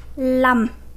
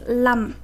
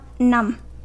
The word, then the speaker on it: lăm: So as you know, the word five is pronounced num, right? But whenever five go after any other number, you will pronounce it lum. For example, 45 is bốn mười lăm. 55 is năm mười lăm. And just ask the just for now is lum, not num.